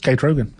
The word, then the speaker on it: Kate Rogan: It's just Kate Rogan.